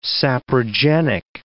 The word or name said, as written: saprogenic